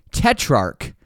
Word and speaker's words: Tetrarch: I'm ready to rock, and we have a great episode with a super intriguing band called Tetrarch. Yes, the spelling may confuse, but don't let it. It's just a silent H.